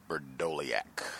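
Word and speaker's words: Vrdolyak: If folks have any points on him, apparently it is Berdoliak.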